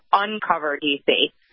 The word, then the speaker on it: Uncover DC: It's Uncover DC. – Did I say it wrong? Okay, let's do it again, okay? – Undercover, like everyone in the world does. – Oh, okay. No, it says uncover. I just, my brain just added another syllable there.